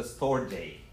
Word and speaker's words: storge: It's pronounced the storge. These are Greek words.